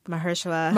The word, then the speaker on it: Mahershala: Mahershala? I can never say his name. – Mahershala.